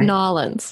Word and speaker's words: New Orleans: but I know I'm saying that wrong. It's New Orleans, right? – New Orleans. – Oh, thank you. – It's one word.